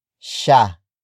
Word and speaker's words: siya: First, we have sha, which is spelled as S-I-Y-A.